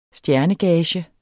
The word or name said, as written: STJERNEGAGE